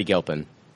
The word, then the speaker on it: Gilpin: Gilpin? – Betty Gilpin.